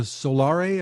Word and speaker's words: Solare: Solare is the Italian word for solar. We decided, hey, solar might be too basic for us, and so we added an E on there, and that's the name of it. So pronunciation's perfect.